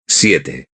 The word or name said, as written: siete